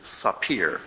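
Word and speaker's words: sapir (ספיר): In Hebrew, it's sapir. And there you can see how it's spelled in Hebrew. Samek, pe, yud, resh. Sapir.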